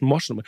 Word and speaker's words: Moschner: Ruth Moschner ist, äh, Rosch, Moschner, Ruth Moschner ist für mich das Christkind der Woche und der Liebling der Woche.